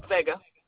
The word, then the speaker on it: Vega: Vega. – Could you spell it? – V-E-G-A.